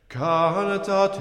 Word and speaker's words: cantate: Cantate Domino, Canticum novum, Alléluia. – Voilà, marquez bien l'accent tonique, cantate. Cantate, pareil sur Canticum Novum.